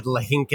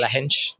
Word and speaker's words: Lahinch: Thank you earlier for correcting my mispronunciation. I said Lahinch. It's not. It's Lahinch. – Lahinch.